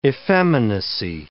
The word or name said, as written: effeminacy